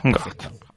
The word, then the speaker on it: En Garde: Como tú, que eres más del francés, que como lo he pronunciado bien. – Sí, está perfecto. En Garde.